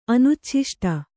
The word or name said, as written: ಅನುಚ್ಛಿಷ್ಟ